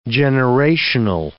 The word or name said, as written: generational